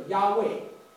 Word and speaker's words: 亚卫: 你知道希伯来文没有母音的，我常常说你这个 YHWH 希伯来话发音叫做亚卫，我们中文把它翻成耶和华啊。